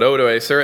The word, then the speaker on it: Odoacer: And that was a loss, and the barbarian king, various pronunciations, but Odoacer executed the last of the Roman emperors, Romulus Augustulus, in 476.